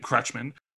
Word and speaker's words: Kretschmann: I believe you say his name Kretschmann, but from The Winter Soldier, and from Age of Ultron, he played Strucker in those.